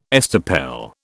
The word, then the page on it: estoppel: British English